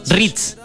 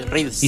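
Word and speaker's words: Threads: Ritz. Sí, Ritz. – Y se escribe eh, T-H-R-E.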